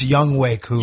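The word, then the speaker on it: Younghoe Koo: It's pronounced Young Way Koo.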